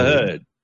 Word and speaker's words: Heard: Amber Heard. – Mm. Rhymes with. – Yeah, rhymes with turd.